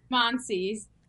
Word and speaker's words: Schmonsees: No one pronounce that to me again. Schmonsee? – Schmonsees. – Okay, Schmonsee, yes.